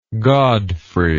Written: godfrey

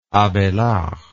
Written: abailard

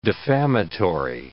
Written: defamatory

